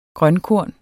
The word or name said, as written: grønkorn